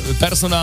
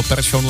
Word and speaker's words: Persson: Takže my jsme Persson a Larsson celé ty roky říkali špatně. Správně je to Persson, Larsson.